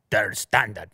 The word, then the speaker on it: Der Standard: Der Standard. That's how they say it. Unless you want to correct me. – No. Correct.